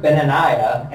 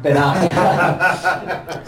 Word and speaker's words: Benaiah: So, I've also heard Benaniah and Benaniah.